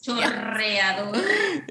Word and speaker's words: chorreador: Like now that you told me, oh, I got a corredor, but it was a chorreador. I told you, chorreador. Yes, fantastic. But I was like, you got it. Excellent. But I was not like, no, what's that? Yes. – Like laughing at me because I completely pronounced it wrong. Yes, because that's a hard word. – it has the two R's, chorreador.